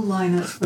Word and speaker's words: Lynott: Well, I would call him Phil Lynott. But there you go. – Not a world authority. – Oh, you are. You are. So it's Phil Lynott and Thin Lizzy with.